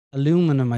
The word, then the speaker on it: aluminum: I remember I was doing aluminium wheels, or aluminum, I think he called it.